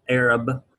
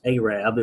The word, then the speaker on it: Arab: That's spelled Arab, pronounced Arab. It was a misspelling by the federal post office, and they decided what the hell, and they stuck with it.